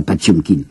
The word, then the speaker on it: Potemkin: Au mois de juillet, il aura la révolte du cuirassé, la mutinerie, vous savez, du cuirassé, de prononcer Potemkin, un hein, cuirassé Potemkin, paraît-il qu'il faut prononcer ça, Pachemkin.